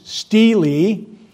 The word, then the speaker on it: stele: It's pronounced with two long E's, stele.